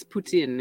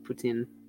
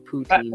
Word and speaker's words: poutine: It's poutine. It's just a little more emphasis on the tin part. Poutine mm. – instead of poutine.